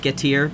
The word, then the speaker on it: Getir: I did not consult Emmeline before this, other than the subject, which Emmeline knows nothing about, which is Getter. Or I was told by someone that's actually pronounced Getir because it's Turkish, but oh. I think I say Getter.